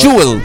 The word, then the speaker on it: Jewel: Chewel, como se... Pero Jewel. – Jewel, es, Jewel. La pronunciación es Jewel.